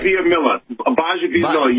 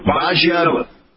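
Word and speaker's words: Bajabiamila: Baja Biamila. You missed the B, but that's it. I mean, yeah, Baja Biamila. – Baja Biamila. Okay. They gave me IAH in the pronunciation, not LAH. If they gave me LAH, I would have had Mila.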